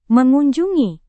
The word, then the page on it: mengunjungi: Indonesian